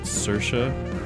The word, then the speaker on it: Saoirse: The song I'm playing right now is called, okay, I think you pronounce it Saoirse. It's spelled the same way as the actress Saoirse Ronan.